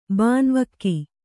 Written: ಬಾನ್ವಕ್ಕಿ

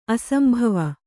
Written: ಅಸಂಭವ

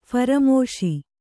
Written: ಫರಮಾಶಿ

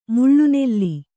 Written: ಮೇಲೆನೆಲ್ಲಿ

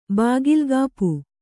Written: ಬಾಗಿಲ್ಗಾಪು

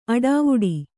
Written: ಅಡಿವಿಡಿ

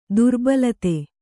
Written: ದುರ್ಬಲತೆ

